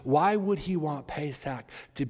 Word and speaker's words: Pesach: It's pronounced in Hebrew, Pesach.